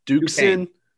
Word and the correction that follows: Duquesne: Duquesne. – Duquesne.